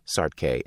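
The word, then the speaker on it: Saartjie: The most infamous medicalized display of a captive black befell Sartke, pronounced Sartke and meaning Little Sarah in Afrikaans, Bartman, who was born in 1789, the year the French Revolution produced the Declaration of the Rights of Man.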